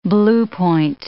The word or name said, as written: blue point